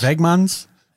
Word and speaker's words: Wegmans: Wegmans. Yeah. – Wegmans.